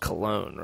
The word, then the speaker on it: Cologne: Just gonna call it colon for the rest of this time. I can't wait. I hope somebody leaves a comment on YouTube or somewhere of this podcast. You know it's pronounced cologne, right? – Yeah, someone will.